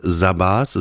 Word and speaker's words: Zabar's: Ich kann ihn nicht richtig aussprechen. Ich glaube, es heißt Sabas. Es wird geschrieben Z A B A R S.